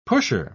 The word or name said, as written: pusher